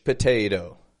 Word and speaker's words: potato: And in p. Unstressed syllable, pate. That te is getting a strong, true T because it is the stress syllable, pate. And then do, flap T, potato. You see how that flap T helps transition from the strong to the weak?